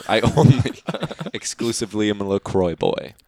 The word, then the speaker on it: LaCroix: Yeah, my LaCroix. Sorry, my LaCroix. Sorry, I pronounced that word wrong.